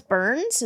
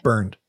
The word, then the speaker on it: Bernd: Well, and I mean, because his name was Burns. Is that how you say it? – Yeah. B-E-R-N-D. – Okay. – Burned.